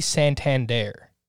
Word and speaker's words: Santander: Is it pronounced like the bank? – I I've listened to Orioles broadcasts, and I've wondered this before too, and they say Santander.